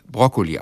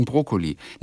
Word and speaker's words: Broccoli: Für mich ist es, also im Italienischen heißt es ja Broccoli. Und im Deutschen sind es ja 2K oder 2C. Es geht beides. Und das müsste man eigentlich Broccoli aussprechen, kurz. Aber ich kenne einige Menschen, die sagen Brokkoli.